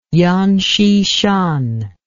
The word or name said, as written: yan xishan